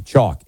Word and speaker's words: Choq: Chalk is spelled C-H-O-Q.